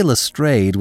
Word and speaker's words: Lestrade: I say Lestrade with a long A because that is how he was first introduced to me when I listened to John Gielgud and Ralph Richardson.